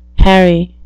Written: harry

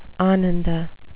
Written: ananda

